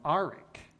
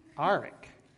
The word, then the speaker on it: Orrick: My grandmother said our last name was Arick. Arick. It's spelled O-R-R-I-C-K, but she pronounced it Arick.